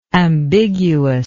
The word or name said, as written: ambiguousness